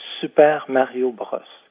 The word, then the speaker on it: Super Mario Bros: Vous l'aurez deviné, je parle ici de Super Mario Bros. C'est comme ça qu'on le prononce en français.